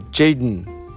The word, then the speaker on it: Jaden: でその 1 枚っていうのが、 95 位、これも一番下の方ですよね、初登場してきたのがですね、名前がこう JXDN と書いて Jaden ですね、 Jaden って読むらしいんですけども、まあ本名が Jaden Isaiah Hustler っていう、これ白人の新人アーティストなんですけども、ね、まだ若干 20 歳っていう。